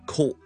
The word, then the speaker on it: court: 照住阿 T court 咧，嗰个 T 咧，好多时候咧，我哋系会将佢唔读啦。但系咧，另一个方式呈现出嚟嘅意思咧，就系将佢呢个 syllable 缩短急停，而呢个急停咧喺喉咙度做嘅，唔系我哋正常 T 音咁喺个脷尖度做嘅 court 脷尖啦，court 喺个喉咙度停咗呢个音 court。